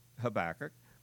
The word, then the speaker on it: Habakkuk: And I decided I like the English rather than calling the old guy a kook all the time. So I still like the English pronunciation of Habakkuk, okay?